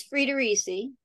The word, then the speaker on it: Friedericy: It's Friedericy. – Yes. – It was originally Dutch. – Wow. – So it just a little equal weight to all of the syllables.